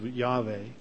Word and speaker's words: Yahweh: either YHVH, or otherwise pronounced Yahweh, or to Elohim, otherwise translated God in the Old Testament.